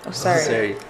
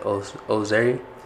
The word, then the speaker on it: Osury: Osury. Yeah. Everybody has trouble pronouncing my name. – It's like a Z, kind of. – Yeah. So like Osury.